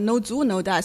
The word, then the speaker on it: No Zuo No Die: Yeah, my cast, later on, all the producers and actors they involved, they always pronounce it like No Zuo No Die. So it's like a zoo.